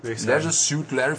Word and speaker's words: Leisure Suit Larry: Das ist so ein bisschen Leisure Suite oder Suit Larry. Heißt der, Su wie spricht man das aus? Suit Larry. Suit. Leisure Suit Larry von Al Lowe.